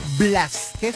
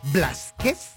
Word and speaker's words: Blázquez: María Fernanda Blázquez, porque tiene el acento en la A. Blázquez Gil.